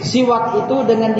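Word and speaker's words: siwak itu dengan (سواك): Asiwaku bikasri sin, kala Syekh, kala Abdul Ghani Al-Maqdisi rahimahullah ta'ala, berkata muallif, penulis kitab Umdatul Ahkam, siwak itu dengan dikasrohkan sin, huruf sinnya dikasroh, si, jadi bacanya si.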